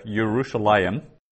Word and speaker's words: Yerushalayim: So we actually pronounce it like that, Yerushalayim, even though the yod isn't there.